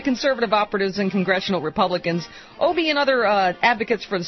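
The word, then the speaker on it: Obey: Obie. – The Wisconsin Democrat on the show. White House Appropriations Committee Chairman David Obie, the Wisconsin Democrat, who has long championed investment in pandemic preparation.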